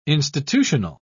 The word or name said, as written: institutional